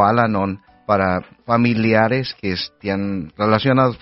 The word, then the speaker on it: Al-Anon: Vamos a revisar también algunos de los eventos que vamos a tener con la Oficina de Vocaciones, pero nos acompaña de Al-Anon, si lo pronuncié bien. Sí. Bienvenida, buen día, ¿cómo estás? – Sí, buenos días, muchas gracias por invitarme. – Con mucho gusto. ¿Sí lo pronuncié bien, Al-Anon? – Sí.